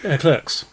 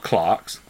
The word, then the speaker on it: Clerks: Not Clarks. Fucking people Clarks. Who call it Clarks. Clarks. It really annoys me. – It's about a shoe shop. – You don't pronounce a French film with the fucking English fucking pronunciation.